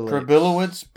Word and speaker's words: Przybylowicz: Przybylowicz. – Przybylowicz. – It looks like pretzel by Lozies. Pretzel by Lozies. That's his name.